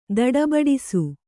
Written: ತಡಬಡಿಸು

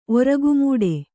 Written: ಒರಗುಮೂಡೆ

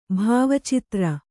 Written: ಭಾವಚಿತ್ರ

